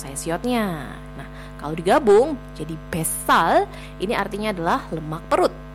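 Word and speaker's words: besal (뱃살): Jadi bacanya jangan dipisah menjadi bet sal, tetapi menjadi besal. Besal, pakai S double ya, jadi pelafalan S yang double itu harus terdengar.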